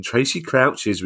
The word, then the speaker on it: Tracy Crouch: And Robert says, how likely is it that the recommendation in Tracy Crouch's report will be listened to? I say it like that because that's how the Baroness says it.